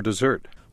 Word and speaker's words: dessert: I'll bring the menu back later for dessert. – 후식 고르실 때 차림표를 다시 가져오겠습니다. 여기서 단어 dessert, 후식 dessert라 그러죠. Desert라고 하면 사막이란 단어가 되죠. 발음, 억양 정확히 구분하시기 바랍니다.